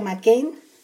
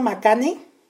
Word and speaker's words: McCann: Es McCain como es M-C-C-A-N-N, así se pronuncia McCain. Yo, yo por poco yo pensé que se pronunciaba Macane, pero eh, ya, ya saben que yo siempre yo le batallo mucho con los nombres, sobre todo con los nombres extranjeros.